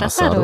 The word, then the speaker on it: asado: Ja, hast du, du bist gut gemacht, Asado, Asado ja.